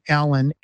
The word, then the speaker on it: Alan: It's my full name, Jon, J-O-N, without the H. My middle name, Alan, A-L-A-N. And my last name, Stewart, spelled like Jon Stewart, the comedian, S-T-E-W-A-R-T.